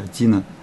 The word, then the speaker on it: Дина: На иврите это не так, на иврите даже имя Дина, оно произносится более мягко, Дина.